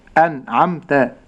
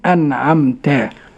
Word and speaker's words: أنعمت: نستمع مرة أخرى إلى هذه الكلمة، أقول أنعمت. – أنعمت. الخطأ المنتشر فضيلة الشيخ في هذه الكلمة هو إعطاء غنة للنون أو قلقلة النون. – نعم. – النطق الصحيح أيها الإخوة المؤمنون أنعمت.